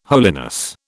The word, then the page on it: holiness: British English